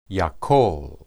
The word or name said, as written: yakol